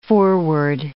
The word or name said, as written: foreword